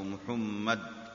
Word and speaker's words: محمد: قال الإمام محمد بن علي الشوكاني، أو محمد، هكذا النطق الصحيح لاسم محمد بن علي،